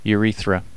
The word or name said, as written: urethra